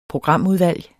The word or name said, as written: programudvalg